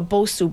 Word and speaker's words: Bosu: And now there's also a Bosu ball. Am I saying that right? – Yes.